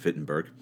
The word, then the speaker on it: Wittenberg: And by the way, that's the name of a town, Wittenberg, in Deutsch, spoken of in, that's how you pronounce it in German.